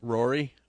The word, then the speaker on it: Ruairi: We've heard that Irish director Ruairi, is that how you pronounce that? R-U-A-I-R-I, Ruairi Robinson. – Rory? – Yeah.